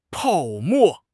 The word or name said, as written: 泡沫